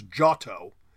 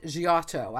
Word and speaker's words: Giotto: The G-I, the I merges into the O in Italian, so after the G, so it becomes Giotto, with the emphasis on the first syllable.